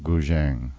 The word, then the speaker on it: guzheng: And that was a group called Sound of China Guzheng, and that's spelled G-U-Z-H-E-N-G.